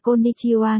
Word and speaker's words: Konnichiwa: Konnichiwa, hai, hai, phát âm là Konnichiwa nghĩa là xin chào,